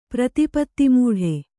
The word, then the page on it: ಪ್ರತಿಪತ್ತಿಮೂಢ: prati patti mūḍha